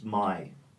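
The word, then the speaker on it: MIH: And it says M-I-H. I like simple things that are easy to pronounce. The H is kind of silent. And it would be pronounced my.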